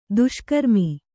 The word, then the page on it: ದುಷ್ಕರ್ಮಿ: kannada; duṣkarmi